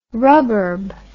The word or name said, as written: ruburb